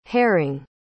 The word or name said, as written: haring